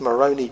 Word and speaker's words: Moroni: And he claimed that the angel Moroni came to him, and as Arnold Fruchtenbaum says, Moroni just happens to rhyme with baloney.